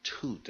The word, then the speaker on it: Thoth: But since he certainly is always thinking of the Egyptian Thoth, you have to ask yourself, would he have known that you really should pronounce that Tut as in Tutankhamun?